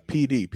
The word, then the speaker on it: Peedee: PD. – It's the PD tribe. – It's a tribe in South Carolina. – Yeah. – What does it stand for? – No, it's PD, P-E-E-D-E-E.